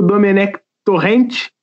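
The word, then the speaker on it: Domènec Torrent: Eu acho que o Flamengo, quanto, quanto antes entender isso melhor, tem o Domenech Torrente. Falei certo, Paulinho? – Gostei.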